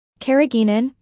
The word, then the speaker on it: carrageenan: Carrageenan, pronounced carrageenan, is an FDA-approved food additive, also known as E407, that is derived from red seaweed and consists of large sugar molecules.